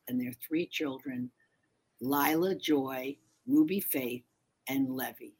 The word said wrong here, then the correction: Levi: Levi is the little boy, but yes. – Levi.